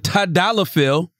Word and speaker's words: tadalafil: And Tadalafil, did I land that one? – Killed it.